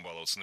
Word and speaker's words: баловаться: Я просто уверен, что это же не только русские так придумали с русским языком баловаться. Наверняка в каждом языке должна быть такая фраза. – Баловаться, баловались, балуем, все это неправильно. В нашем глаголе баловать, баловать. Ударение всегда стоит за корнем, поэтому баловались, балуем.